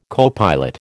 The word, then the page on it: copilot: American English